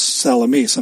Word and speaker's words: Salamis: that it's pronounced Salamis. I'm sorry. Uh, you may have been fantasizing about a nice cold cut sandwich or a loaded pizza and if you weren't, well, then you are now, so sorry.